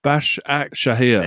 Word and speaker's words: Başakşehir: Bash Ak Shahir.